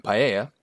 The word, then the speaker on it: paella: I hope you enjoy my pronunciation of paella. My wife made sure that it was correct.